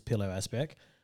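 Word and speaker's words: Pilou Asbæk: We also got really hard name to pronounce, Pilo Asbeck, who was.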